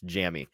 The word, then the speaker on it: Jammie: I mean, it's spelled double M. So I'm almost positive I've heard it pronounced Jammy.